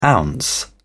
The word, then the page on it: ounce: British English